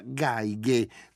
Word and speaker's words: Geige: Ora Mimmo da Cremona ci scrive che si pronuncia Geige come J-J, cioè violino in tedesco. Io qui leggo G-A-I-G-G, può essere che lui abbia ragione, ci credo sulla parola.